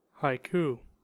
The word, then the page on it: haiku: American English